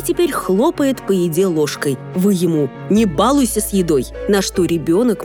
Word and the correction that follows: балуйся: может ответить: надо говорить «не балуйся», и будет прав. Ударение здесь следует ставить на второй слог.